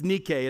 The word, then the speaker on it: Nike: It's Nike in the Greek. We pronounce it Nike.